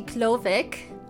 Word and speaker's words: Glowick: It's actually Glowick, and it's because it's a German name. We turned that W to a V.